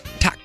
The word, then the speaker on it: tak: It means yes, but the pronunciation is tak.